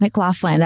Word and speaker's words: McLaughlin: I believe you did. It's McLaughlin. That was perfect.